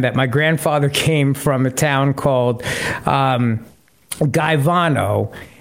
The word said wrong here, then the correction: Caivano: And when we went to Italy on our honeymoon, I went to the town, but it's Caivano, and that's how they say it over there, Caivano. And so I was asking for Gaivano, and they had no idea what I was talking about. They finally said Caivano, and they understood it.